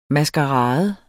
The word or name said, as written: MASKERADEN